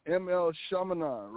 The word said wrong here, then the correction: M. Night Shyamalan: And now say his name. Say his name again. – M. Night Shyamalan.